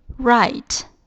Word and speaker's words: write: 写，write, write, w r i t e, w r i t e。我们第一次接触用 w 和 r 开头的单词，w 和 r 发音和 r 是一样的，都是 r，i 发的就是 i 的音，然后 t 发的是 t，最后的 e 是不发音的。